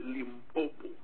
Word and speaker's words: Limpopo: In Limpopo, sometimes we don't pronounce it correctly. It's Limpopo.